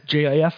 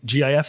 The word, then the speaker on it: GIF: Yeah, because I think it's because the one side has JIF and it says soft G pronunciation, and then the other one is GIF and it says hard G pronunciation.